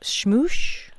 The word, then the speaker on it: smoosh: I want to make sure I caught that. Schmoosh? – Yeah. It's spelled S-M-O-O-S-H. – Just like it sounds.